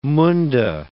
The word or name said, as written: Munda